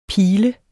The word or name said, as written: pil